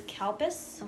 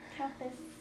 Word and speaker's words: Calpis: But it's it's originally known as Calpis, uh-huh. which sounds a lot like cow piss. Yeah. – Yeah. – Which is fun. – Calpis.